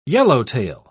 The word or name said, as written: yellowtail